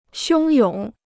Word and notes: 汹涌: xiōng yǒng

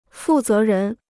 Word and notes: 负责人: fù zé rén

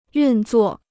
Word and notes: 运作: yùn zuò

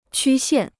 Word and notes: 曲线: qū xiàn

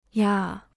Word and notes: 呀: ya

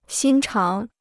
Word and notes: 心肠: xīn cháng

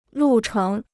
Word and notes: 路程: lù chéng

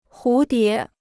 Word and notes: 蝴蝶: hú dié

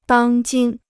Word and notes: 当今: dāng jīn

